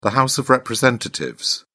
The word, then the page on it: House of Representatives: British English